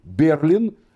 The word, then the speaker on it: Берлин: Нам для этого понадобится один из моих любимых комментариев к Торе, который написал раввин с очень красивой фамилией Берлин. Это так же, как Берлин, только ударение немножко другое.